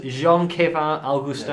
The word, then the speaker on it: Jean-Kévin Augustin: Number three, slightly left field, Jean-Kévin Augustin.